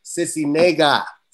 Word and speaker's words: Sisniega: And without no further ado, we have the beautiful Faride Sisniega.